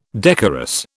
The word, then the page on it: decorous: British English